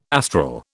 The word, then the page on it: astral: British English